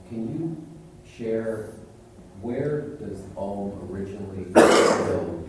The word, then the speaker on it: Om: In Sanskrit language, Om consists of three syllables, A, U, and Ma.